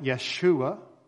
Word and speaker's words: Yeshua: And perhaps Aramaic speakers would have called it Yeshua. And with Yeshua, perhaps you can hear the way we pronounce Joshua. But again, in Hebrew, there's no J sound, and so it's kind of a Y sound. So if you don't say Joshua, then you've got Yahshua or Yeshua.